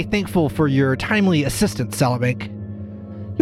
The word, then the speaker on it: Salabank: It's Salabank. – Can you spell that for me? – S-A-L-A Bank with three Q's.